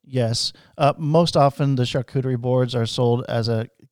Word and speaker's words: charcuterie: Charcuterie. Did I say that right? – You did, yeah. Charcuterie.